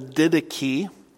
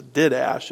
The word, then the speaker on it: Didache: By the way, don't pronounce that the Didache. I was talking to one of my friends on the phone and he kept calling it the Didache. And no, that's not pronounced the Didache, it's the Didache.